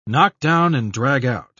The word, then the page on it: knock-down-and-drag-out: British English